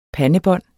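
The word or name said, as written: PANDEBÅND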